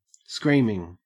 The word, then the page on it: screaming: Australian English